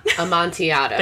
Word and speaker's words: Amontillado: Amontillado.